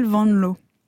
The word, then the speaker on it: Van Loo: Oui, mais je vous ai demandé avant, j'ai un peu triché, parce qu'évidemment, oui, nous, les Français, on prononce mal les langues, et on a envie de prononcer le double O ou.